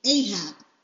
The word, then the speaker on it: Ahab: Okay, and it will be Ahab.